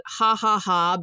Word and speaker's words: Jajaja: It's called Ha Ha Ha, but it's spelled J-A-J-A-J-A for Spanish.